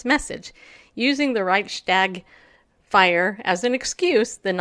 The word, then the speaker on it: Reichstag: Reichstag fire." Did I say that right? – Reichstag, yes.